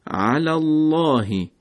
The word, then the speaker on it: على الله: یا کلمه الله که اگر دقت بفرمایید در این عبارت قبل از کلمه الله علامت فتحه هست و باعث میشه لام در کلمه الله درشت و پرحجم بشه که تلفظ میکنیم علی اللهی علی اللهی.